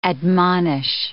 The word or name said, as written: admonish